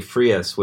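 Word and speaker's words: Freas: Since we had mentioned the Queen album, Frank Kelly Frias was the artist on that. – Editor's note, it's actually pronounced like freeze.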